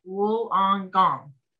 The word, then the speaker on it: Wollongong: Linda said it's pronounced Wollongong.